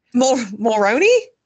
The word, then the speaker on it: Mulroney: Is it Mulrooney? MacRooney? – Mulroney? – Mulroney, M-L-U-R, okay.